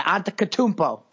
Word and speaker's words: Antetokounmpo: Antetokounmpo. – You gotta just go for it. You can't think about it. Antetokounmpo.